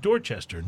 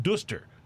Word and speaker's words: Dorchester: But they pronounce it Dorchester, not Duster.